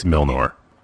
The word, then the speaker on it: Milnor: It's Milnor. – It's Milner. You even pronounced it wrong, so what's up? – Is it Mjolnir, like the hammer that Thor wields? – It's M-O-N-O-R. – I think it was a hammer man. – Milnor. – Are you sure there's not, like, a silent J in there somewhere? – Positive.